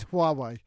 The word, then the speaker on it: Huawei: Well, actually, on the spying theme, how do you pronounce this? You know, Huawei. – Huawei. You could say Huawei. – Huawei. – Yeah, Huawei. I mean, there is a lot of problems, but I believe it's Huawei.